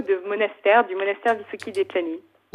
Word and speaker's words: Dečani: Vous allez me dire si je prononce bien ou pas. C'est le monastère de Visoki Dečani, Dečani. – C'est presque parfait, Daniel.